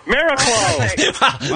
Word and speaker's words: Miraclo: Miraclo? That's how you pronounce it? – Yes. Miraclo.